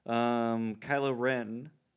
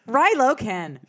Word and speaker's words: Kylo Ren: Kylo Ren. I said it right this time. – God, you man. Did. – Yeah. – Rilo Ken.